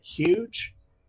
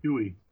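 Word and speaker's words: Huw: H-U-W-J. Is it huge? – Huey. – Huey? H-U-W-J?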